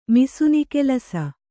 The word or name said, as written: ಮಿಸುನಿಗೆಲಸ